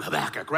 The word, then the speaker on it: Habakkuk: And to do that, what I'd love for us to look at is the prophet Habakkuk. Now you got to say his name with some phlegm, Habakkuk, right? Because he's Hebrew, right?